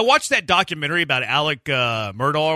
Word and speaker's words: Murdaugh: But it's pronounced like Murdaugh.